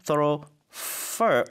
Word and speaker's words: thoroughfare: The main thoroughfare or fair. – Mm-hmm. – 이게 발음이 F-A-R-E가 영국 쪽은 fair 그리고 미국 쪽은 fur로 발음을 하더라고요.